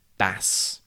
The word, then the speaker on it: bass: B-A-S-S can be pronounced bass, and it means a type of fish, and bass, and it is a type of musical instrument. T-E-A-R is pronounced tear when it is something that comes out of your eye when you cry.